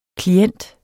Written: klient

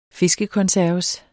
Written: fiskekonserves

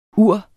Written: uren

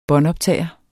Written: båndoptager

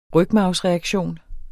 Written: rygmarvsreaktion

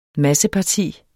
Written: masseparti

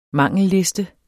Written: mangelliste

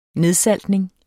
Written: nedsaltning

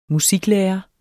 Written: musiklærer

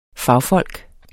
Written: fagfolk

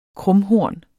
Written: krumhorn

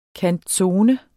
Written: canzone

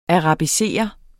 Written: arabiserer

